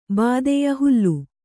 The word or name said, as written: ಭಿದ್ಯ